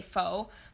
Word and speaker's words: pho: And if you haven't had pho before, it's spelled pho, and it's actually a lot of people say pho, but it's actually pronounced pho.